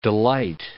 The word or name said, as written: delight